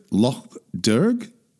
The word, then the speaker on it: Loch Derg: is it Loch Derg? Is that right? – It is, indeed. – Okay. – Yes. – Talk us through those journeys, your mother and father's pilgrimages to Loch Derg.